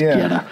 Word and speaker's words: goetta: It's spelled G-O-E-T-T-A, but I think the pronunciation is Geta.